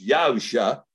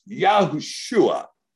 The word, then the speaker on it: Yahushua: Oh no, that's not pronounced Yahusha because we put a vowel sound in there. See, I just went ahead and put this surig right in there, right there next to the shin. Now it's Yahushua.